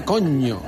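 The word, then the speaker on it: Cogne: Ma la pronunciación es coño.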